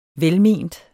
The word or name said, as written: velment